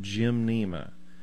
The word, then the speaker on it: gymnema: It's called gymnema. And I will attempt to spell that for you. It's G-Y-M, just like you're going to go to the gym and work out. – Mm-hmm. – But it's one word. Gymnema is spelled G-Y-M-N-E-M-A.